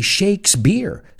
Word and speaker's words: Shakespeare: We say Shakespeare. So if you say he shakes beer, el agita cerveza.